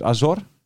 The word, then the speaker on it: Azor: Azor, hij deed de klemtoon anders. Ik zeg altijd Azor, maar het is Azor.